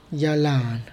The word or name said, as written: yagalana